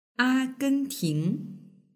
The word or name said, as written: agenting